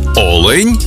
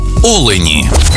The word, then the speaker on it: олень: І в однині, і в множині наголос падає на перший склад. Олень та олені.